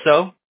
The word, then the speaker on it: So: I'm half Korean, pronounced So, but that is my last name, yeah.